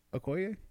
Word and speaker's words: Okoye: Okoye? Mm-hmm. – Okoye. I have never learned her name till right now. I just always called her the Dora Milaje leader. – Yeah, but she's great. I like Okoye.